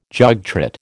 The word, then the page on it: jogtrot: American English